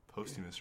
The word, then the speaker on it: posthumous: Is that how you say it? Is it Phosphorus? – Posthumous, yeah.